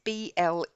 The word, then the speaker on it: Bledsoe: I hope I've pronounced that correctly. It's Bledsoe.